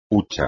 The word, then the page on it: hucha: espagnol